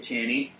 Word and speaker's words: Chani: And then when we go back and see Paul again, he's with his wife, Chani. How do you pronounce that? – I'd say Chani.